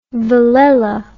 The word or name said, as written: villella